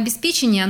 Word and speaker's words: обеспечение: А где должно стоять ударение в отглагольных существительных? Где у глагола, там и существительное. – Угу. – Поэтому обеспечить обеспечение.